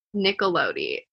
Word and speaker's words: Nicolodi: How do you say your last name? – Nicolodi. It's like Nickelodeon, but stops at the Nickelodeon.